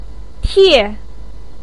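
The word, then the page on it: tie: tone 4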